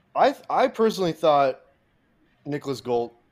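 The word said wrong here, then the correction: Hoult: is that how you pronounce his last name? Gholt? – Holt. Yeah. Holt.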